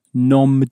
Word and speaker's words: nom: For instance, we might say nom de plume, except we don't pronounce it very correctly because name is pronounced nom. – It's spelt N-O-M, but is M in for luck? No, it isn't. And so the M is not clearly pronounced.